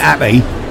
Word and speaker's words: Appy: So, buy Appy is actually without the H.